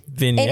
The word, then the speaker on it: vignette: It's like V-I-G-I-N-N-I-N-E-T-T-E. – No, V-I-G-N-E-T-T-E. So vignette.